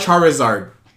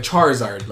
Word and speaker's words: Charizard: Charizard. – Charizard. – Like Charizard. Like Char and then Zard.